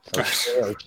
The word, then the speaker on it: O'Shea: O'Shea.